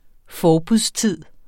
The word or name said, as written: forbudstid